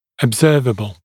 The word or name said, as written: observable